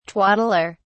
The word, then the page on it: twaddler: British English